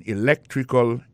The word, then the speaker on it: electrical: I majored in electrical engineering. Electrical engineering. Ça veut dire accent tombé dans electrical là, sur le deuxième syllabe là. Electrical engineering. C'est sur le deuxième syllabe là. Elect là. Plus appuyé.